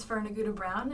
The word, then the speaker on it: Fern Aguda-Brown: And my name is Fern Aguda-Brown, and I am the EST Coordinator at CVU. – If I wasn't so afraid of pronouncing it wrong, that's exactly how I would have pronounced it.